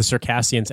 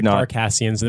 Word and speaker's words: Circassians: Riley mispronounced the name of the Circassians as the Carcassians.